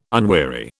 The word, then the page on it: unweary: British English